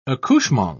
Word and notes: accouchement: British English

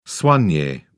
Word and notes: soigne: British English

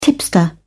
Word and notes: tipster: British English

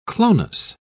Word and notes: clonus: British English

